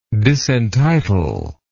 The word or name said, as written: disentitlement